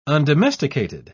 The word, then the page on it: undomesticated: British English